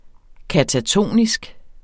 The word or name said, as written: katatonisk